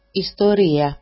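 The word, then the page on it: ιστορία: istori-a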